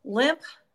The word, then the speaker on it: Lemp: Limp, and it's spelled L-E-M-P, Limp Mansion in St. Louis is so dang active.